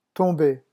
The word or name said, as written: tomber